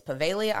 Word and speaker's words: Poveglia: So let's get into the history that led to why Poveglia Island, which is spelt looking like Poveglia Island because it has a G in it. And I definitely, I hate when there's extra letters and I'm like, why do you pronounce it like that when there's clearly a G? But it's Poveglia Island, also known as the Island of Ghosts or the Island of No Return, the Island of the Dead.